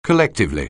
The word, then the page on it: collectively: anglais